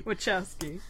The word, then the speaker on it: Wachowski: Wachowski.